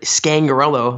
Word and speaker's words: Scangarello: And then he removed Gary Kubiak at the behest of Vic Fangio, more than likely, and installed Rick Scangarello. Am I pronouncing that correctly, Chad? – You got it.